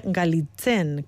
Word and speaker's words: Galitzine: que realmente se escribe Galitzine, con T-Z.